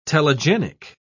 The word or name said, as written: telegenic